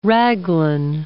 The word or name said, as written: raglan